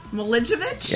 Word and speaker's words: Milicevic: It's Milicevic. – Milicevic? – Yes.